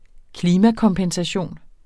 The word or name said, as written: klimakompensation